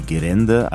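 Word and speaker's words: Gerände (ゲレンデ): Ausgesprochen wird das eher wie Gerände, also R für L.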